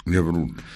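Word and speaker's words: Yabrud: Monseñor Jan Abdob Arbach, arzobispo de la archidiócesis greco-católica melquita de Homs, Hama y Yabrut, ¿se dice así? – Yabrut.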